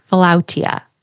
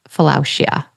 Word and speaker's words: philautia: And it was called in the ancient Greek, philautia. Now we would say it as philautia is how we would pronounce it.